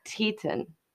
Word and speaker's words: Teton: The same place that I've been mispronouncing? – And it was. – Okay. – It was. So Teton. – Teton. – Okay. Yeah. Grand Teton.